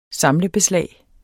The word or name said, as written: samlebeslag